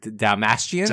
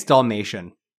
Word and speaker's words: Dasmalchian: This is why I asked for pronunciation of names beforehand. Dasmalchian. – I love Dasmalchian. – David Dasmalchian. – Dothrakian. – I always think it's Dalmatian. Like, Dalmatian, and I know there's no L in there, but Dalmatian.